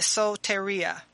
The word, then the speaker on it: soteria: So the Greek word today is soteria. – Soteria? – Soteria. It is spelled S-O-T-E-R-I-A.